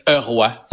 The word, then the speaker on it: Roy: Je suis content, Isabelle tu as dit Patrick Heroy. Oui. Je, te félicite. comme, ça doit être prononcé au Québec.